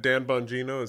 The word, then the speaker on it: Bongino: Even though I think he would qualify, but I think Dan Bongino is that how you say his name? – Yes. He's a strategic investor and partner as well.